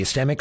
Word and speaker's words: ischemic: Ischemic, and correct me if I'm mispronouncing that, the ischemic stroke? – Yeah, ischemic. Uh-huh. – Ischemic, thank you.